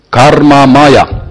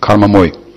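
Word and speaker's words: karma maya (मय): And this is Maya with short A. There is Maya, Maya. So that's the big Maya energy of Krishna, and with the short arm, karma maya, or in Bengali they will say karma moy, because they pronounce short a as o, karma moy.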